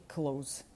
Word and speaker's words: clothes: Простий шлях, який абсолютно зробить вашу вимову цих слів близькою до нативної, це просто забити на всі «the», «с» і говорити «months» і «close».